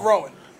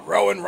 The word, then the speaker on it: Rowan: Because Vince is probably in the back like, oh, Rowan rhymes with Samoan and Roman Samoan.